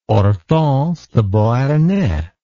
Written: hortense de beauharnais

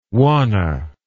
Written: wanna

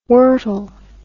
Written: wortle

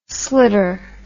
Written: slitter

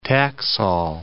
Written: Taxol

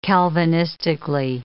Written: Calvinistically

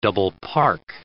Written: double-park

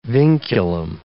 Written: vinculum

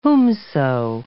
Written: whomso

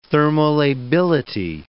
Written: thermolability

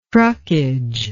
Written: truckage

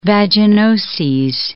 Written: vaginoses

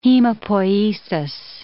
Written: hemopoiesis

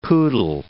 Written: poodle

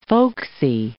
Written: folksy

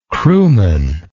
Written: crewman

